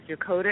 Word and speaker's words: Yokota: How did I pronounce the base correctly? Yokota? – Yes, Yokota Air Force Base, Japan, though.